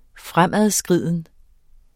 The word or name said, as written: fremadskriden